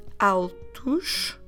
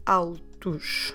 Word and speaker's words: altos: altos. So we're just adding S at alto. Alto, altos. Although it ends at the letters O, S, our pronunciation should be os. Os, altos. And then, for the feminine plural, altas.